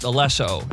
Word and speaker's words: Alesso: It's actually pronounced Alesso, and he's just the guy makes the beats.